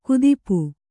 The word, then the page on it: ಕುದಿಪು: kudipu